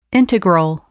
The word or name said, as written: integral